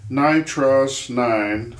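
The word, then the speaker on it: Nitros9: He says, I've always pronounced it Nitros 9 and haven't ever really heard Nitro S9 before.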